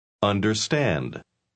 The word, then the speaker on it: understand: Understand. Understand. – ¿Oyó usted el sonido de la R norteamericana en la palabra understand? – La R norteamericana nunca se pronuncia como la R en español.